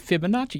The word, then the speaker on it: Fibonacci: Fibonacci.